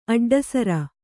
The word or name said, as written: ಅಡ್ಡಸರ